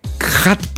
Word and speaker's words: khat: Γράφεται, και εγώ το έμαθα επισκεπτόμενος, ενώ το έμαθα ως πληροφορία, KHAT, δηλαδή χατ προφέρεται, αυτό προφέρουν, αλλά είναι χατ, το οποίο πρέπει να είναι πολύ σκληρή παραλλαγή κόκας, έτσι, να...